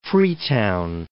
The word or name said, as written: Freetown